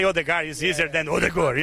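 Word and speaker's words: Ødegaard: What about Odegur? Odegar? – Odegor. – Odegur? – Yeah. – In Brazil, we say Odegar.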